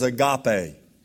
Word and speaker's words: agape: The first one is the one that's used here, and that is agape, A-G-A-P-E, long E.